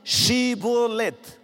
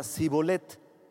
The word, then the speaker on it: Shibolet: Entonces le decían, ahora pues, di Shibolet. Y él decía, Sibolet. No, no, no, di Shibolet. A ver, digan Shibolet. Y él decía, Sibolet, Sibolet. Porque no podía pronunciarlo correctamente.